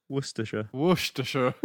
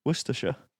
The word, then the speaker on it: Worcestershire: But you don't say Worcestershire. The Shire just disappears. – So how would you say that? – Worcestershire.